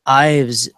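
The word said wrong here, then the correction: Yves: Is that right, Joel? – No, it's Eve.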